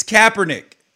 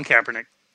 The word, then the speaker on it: Kaepernick: It is not Kaepernick. It's Kaepernick. – All right. Colin Kaepernick.